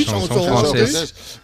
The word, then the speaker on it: chanson française: Mito de la chanson française, ¿se dice así también? – Chanson, sí, chanson française.